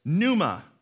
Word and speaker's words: pneuma: In the New Testament, we go to the word in the Greek, and here is the word pneuma. So the P in this word is silent, and it's a current of air, a blast of breath, a strong breeze.